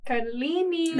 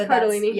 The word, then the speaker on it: Cardellini: Cardellini. – That's who plays Lindsay. I don't know if I ever said that. – Yeah, it's Cardellini.